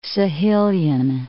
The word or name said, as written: Sahelian